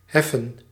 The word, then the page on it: heave: American English; [hiːv]